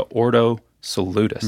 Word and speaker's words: Ordo Salutis: Ordo Salutis.